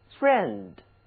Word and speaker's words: friend: 来读一下这个温情脉脉的词，其中的 I E 这两个字母结合起来呢，就读作 a，它就是把爱这个字的发音口型再稍微扁一点，来跟我读，爱我们的朋友，a friend。